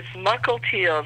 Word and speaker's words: Mukilteo: It's Mukilteo. It's spelled M-U-K-I-L-T-E-O.